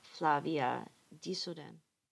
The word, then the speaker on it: Flavia Dzodan: By Flavia Dzodan. – Great pronunciation of her name.